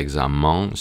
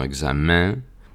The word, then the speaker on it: examen: Entre las excepciones a esta regla tenemos la palabra examen, que no se pronuncia examen, sino examen, y también las combinaciones de las letras IN o E acentuado EN.